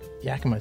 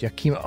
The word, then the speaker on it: Yakima: Yakima! – Oh. It's not Yakima, it's Yakima.